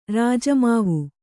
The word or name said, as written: ರಾಜಮಾವು